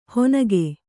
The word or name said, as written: ಹೊನಗೆ